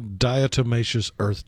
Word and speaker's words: Diatomaceous Earth: National Diatomaceous Earth Day.